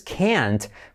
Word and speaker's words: can't: Por cierto, una cosa importante de este verbo can es que la negación es can't, pero en realidad esa T no se pronuncia, sino lo que hacemos es enfatizar toda la palabra.